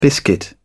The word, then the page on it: biscuit: British English